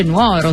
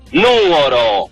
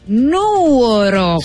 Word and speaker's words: Nuoro: Quanto mi riguarda invece una ricetta molto rara e preziosa che arriva da Nuoro, ma non si dice Nuoro. – Sentiamo. La settimana ho imparato che non si dice Nuoro, ma si dice Nuoro. – Nuoro, si con l'accento sulla U.